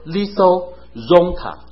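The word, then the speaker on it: Litho Zonta (λίθον ζῶντα): 这个希腊字呢就读成说 Litho Zonta。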